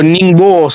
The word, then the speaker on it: Ningbo: Correcto, Gaby, ahí en Nimbo, o, Nimbo Ningbo sería, eh, eh, según la, la, pronunciación en chino,